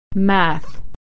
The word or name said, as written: math